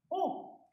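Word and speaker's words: eau: Es o oh, significa... – Agua, pero es, eh, se escribe E-A-U. E-A-U.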